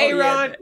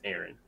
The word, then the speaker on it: Aaron: yeah, A. Ron. – the A. Oh, you've heard people pronounce that as A. Ron? – A. Ron. Like seriously. They're like, no, it's Aaron.